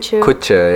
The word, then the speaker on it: Kutcher: Ashton Kushotahe. – Is that how you pronounce his name? – I think Kutcher, yeah.